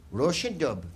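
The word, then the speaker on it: Róisín Dubh: Do you know how to pronounce Roisondubh? Roisondubh. That's what it's pronounced. Well, that's where we'll be. I've never been able to pronounce it. To me, it's Rosendubs.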